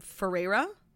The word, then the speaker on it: Ferreira: Like, I know Barbie, I'm sorry, I can never pronounce her last name, Ferreira? – Yeah.